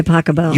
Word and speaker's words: Pachelbel: Canon D by Pasquale. – By Pachelbel. – You pronounce it Pasquale? – No.